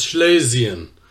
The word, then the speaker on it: Schlesien: So I decided to check it out, actually, and I found out that in German, it's pronounced as Schlesien.